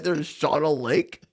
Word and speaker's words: Shawano: How do you pronounce it? – Shawano? – I say Shawano. – Do you know that my dad and stepmom live there? – Yeah. I didn't know that, but they live in Shawano because sorry, I'm a child of phonics. – No, it's pronounced Shawano.